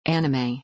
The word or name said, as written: Anime